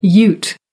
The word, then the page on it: ute: American English